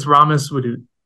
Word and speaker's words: Ramis Wadood: Yes, it's it's Ramis Wadood.